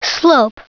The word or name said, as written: slope